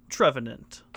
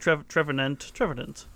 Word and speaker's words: Trevenant: But regardless of our poorly worded metaphors, Phantump has evolved into its new form, Trevenant. – Now, have you done the official pronunciation search? – Yeah, on Trevenant? – They kind of say Nunt instead of Nant, like Revenant.